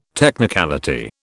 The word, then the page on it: technicality: British English